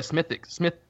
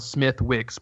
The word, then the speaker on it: Smithwick's: Well, I like a Smithwick's, which is spelled Smithwick's, but it's pronounced differently.